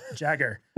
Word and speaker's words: Jagger: Sometimes I pronounce it Jaeger because I, you know, for whatever reason, I'm terrible with names. But he said, "Hey." He was like, it's pronounced like my Uncle Mick's last name, Jagger.